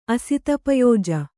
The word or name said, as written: ಅಸಿತಪಯೋಜ